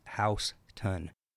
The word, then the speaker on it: Houston: It's pronounced House-ton, not like the city in Texas.